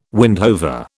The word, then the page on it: windhover: British English